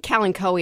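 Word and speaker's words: Kalanchoe: And actually, I think calanchoe is actually the correct pronunciation. It's K-A-L-A-N-C-H-O-E.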